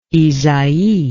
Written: ysaye

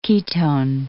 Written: ketone

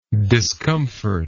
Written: discomfortingly

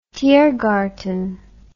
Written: Tiergarten

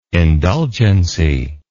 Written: indulgency

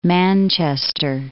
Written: Manchester

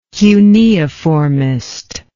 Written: cuneiformist

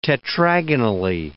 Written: tetragonally

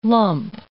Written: lump

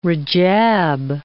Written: Rajab

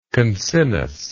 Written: concinnously

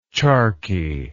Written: charqui

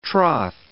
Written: troth

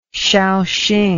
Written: shaoxing

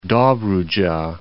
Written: Dobrudja

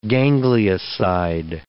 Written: ganglioside

